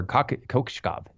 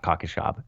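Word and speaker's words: Kokoskov: Kokoshkov. Excuse me, not Kokoshkov, Kokoshkov.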